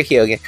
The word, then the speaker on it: Keoghan: Barry Keoghan, which I know that's not how you say his name. – Barry Keoghan. – It is Keoghan. You are correct. – You do hit the O.